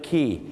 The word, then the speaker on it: quay: ออสเตรเลียฮ่องกงหรือสิงคโปร์เนี่ยนะครับบางครั้งเนี่ยนะครับเราจะเห็นคำว่าท่าเรือแบบนี้ครับ QUAY ซึ่งหลายคนนั้นไม่เคยแน่ใจต้องออกเสียงไงครับไปสิงคโปร์ไปฮ่องกงอังกฤษออสเตรเลียไปบอกควายแน่ท่าเรือที่รับผู้โดยสารเนี่ยนะครับเขาจะออกเสียงว่าคีครับผมถูกต้องเลยครับอย่างเช่นไปที่ออสเตรเลียที่ซิดนีย์ Circular Quay